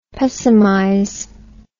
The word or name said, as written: pessimize